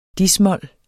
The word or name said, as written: dis-mol